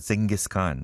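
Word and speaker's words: Dschinghis Khan: 뭐 I guess it is pronounced Genghis Khan, right? But it is spelled in a German way.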